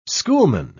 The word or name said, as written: schoolman